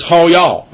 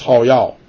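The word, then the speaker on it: hayah: And it's ha-yah. Actually, it's pronounced ha-yah.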